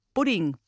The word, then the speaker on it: Pudding: Pudding würde zum Beispiel eher wie Budding, Budding ausgesprochen.